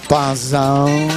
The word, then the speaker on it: puzzle: Si scrive puzzle e si pronuncia puzzle.